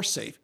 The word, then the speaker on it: Saiph: But poor SAFE, which is spelled S-A-I-P-H.